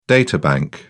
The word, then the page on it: databank: British English